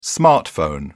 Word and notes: smartphone: British English

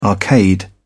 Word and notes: arcade: British English

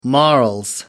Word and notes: morals: British English

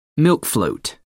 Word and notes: milk float: American English

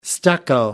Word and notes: stucco: American English

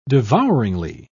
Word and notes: devouringly: British English

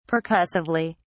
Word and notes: percussively: British English

